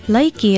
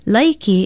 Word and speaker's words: Laiki: Laiki is spelled L-A-I-K-I and pronounced laiki.